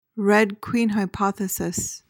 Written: red queen hypothesis

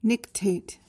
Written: nictate

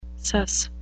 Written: suss

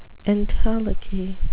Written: entelechy

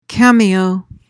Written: cameo

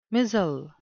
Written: mizzle

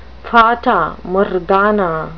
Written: fata morgana

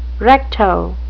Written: recto

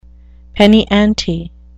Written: penny-ante

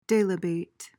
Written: delibate

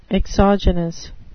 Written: exogenous

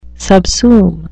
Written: subsume

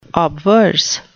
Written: obverse

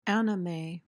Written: anime

